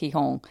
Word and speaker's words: Gijón: but we're very confident it's Gijón. In fact, we're certain it's Gijón, both because Matt is a Spanish speaker and because everyone involved with the tournament was calling it Gijón.